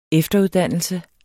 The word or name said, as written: EFTERUDDANNELSEN